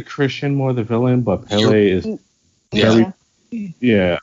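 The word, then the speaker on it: Pelle: So what do we think about the character of Pele? Or they call him Pel sometimes, but I think it's Pele. That's how they pronounce it.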